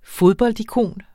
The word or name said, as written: fodboldikon